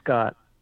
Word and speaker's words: Skaughty: I pronounce it Scott because it looks like cot with an S in front of it. – That's exactly right. Yeah, it's Scott.